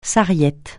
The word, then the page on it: sarriette: francais